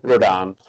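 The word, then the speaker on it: Rodón: Rodon.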